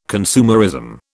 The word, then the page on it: consumerism: British English